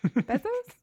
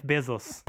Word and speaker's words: Bezos: Bezos? How do you say his name? I've never said it out loud. – I believe it's pronounced Jeff Bezos.